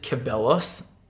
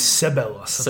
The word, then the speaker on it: Ceballos: I think we pronounce it Ceballos in the off-season when he came on loan. – It's Ceballos, apparently. – Ceballos. Yeah, he had two cracker assists in his Two cracker assists, bossed the midfield.